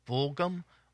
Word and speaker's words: Fulghum: F-U-L-G-H-U-M. I don't know if that's Fulghum or I guess that's the way you would pronounce it, Fulghum.